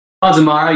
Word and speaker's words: Bonds of Mara: You might maybe remember him from a band called My Darkest Days and Bonds of Mara. Did I say that right? – Bonds of Mara. Yeah, that's pretty damn close.